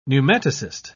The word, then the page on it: pneumatocyst: British English